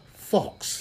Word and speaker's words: faux: Fox. What's that? F-A-U-X? How do you pronounce that shit? You know? Fox.